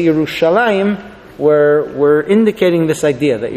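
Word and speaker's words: Yerushalayim (ירושלים): Whereas the pronunciation was always Yerushalayim. That's our tradition.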